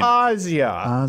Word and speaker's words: Asia: You know, when it's spelled like I'm going to say Asia. – Am I the only one? Well, like, that's. I think that's it's fine. Asia.